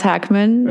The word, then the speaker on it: Hackman: Oh, I'm just watching movies, unfortunately, and he's spelled with a K, so it's pronounced Hackman, yeah.